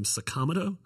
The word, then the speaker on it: Sakamoto: There's a man whose last name was Sakamoto, and he pronounces his name Sakamoto.